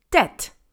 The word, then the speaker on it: debt: The letter B in this word is silent, so we don't pronounce it. The word is debt, so it only has three sounds in there. D, E, T. Debt.